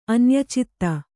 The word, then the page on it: ಅನ್ಯಚಿತ್ತ: kannada